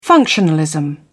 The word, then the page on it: functionalism: American English